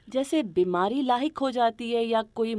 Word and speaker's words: لاحق: ہاں اس کا صحیح تلفظ وہی ہے جو ہم لوگ بولتے ہیں، یعنی لاحق زیر کے ساتھ۔